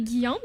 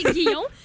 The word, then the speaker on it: Guyon: C'est ça, parce que, tu sais, mon nom de famille, c'est Guillon, mais ça s'écrit G-U-Y-O-N, comme Guy, qu'on rajoute un on à côté, Guillon.